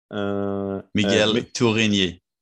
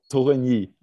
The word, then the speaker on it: Tourigny: Miguel Tourigny. – Tourigny, okay.